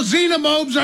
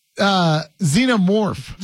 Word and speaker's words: Xenomorph: Well, no, xenomobes are the... – No. Okay, Silent K, thank you, because I've never seen the movie. Xenomorph.